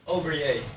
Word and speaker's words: Aubergé: Hoe spreek je jouw achternaam uit, Koen? Koen? – Aubergé. – Oh, Aubergé. Oké, dat is het. Ja, het is een aparte achternaam. – Ik noem ook wel eens Aubergé.